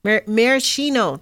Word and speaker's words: maraschino: Anyway, tomato products, cakes, jams, cherries, maraschino, that's how you say it, maraschino cherries.